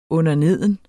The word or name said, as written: underneden